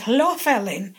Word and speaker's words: Helvellyn: Well, it's almost Welsh, is this. It, like Hlofellyn, and it's the hunting ground.